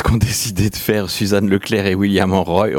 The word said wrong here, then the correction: Roy: Roy pardon. Je vais pas le prononcer à l'américaine, mais à la française, William Roy en vous proposant un bel album autour de Freud.